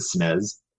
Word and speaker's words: SNES: Did you just pronounce it SNES? – That is how you pronounce it.